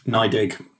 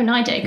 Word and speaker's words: NYDIG: says crypto custody firm NYDIG. How am I pronouncing that? Is that right? – NYDIG. – NYDIG. Oh, NYDIG.